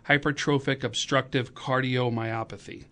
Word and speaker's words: hypertrophic obstructive cardiomyopathy: And it was diagnosed, and if I pronounce this wrong, feel free to correct me, right? But hypertrophic obstructive cardiomyopathy. – Yes, that's correct.